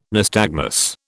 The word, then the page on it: nystagmus: British English